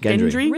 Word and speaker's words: Gendry: So, you mentioned that the Red Woman put the blood of, is it Gendry or Gendry? – Gendry. – Really? – Gendry. – I thought it was a soft G.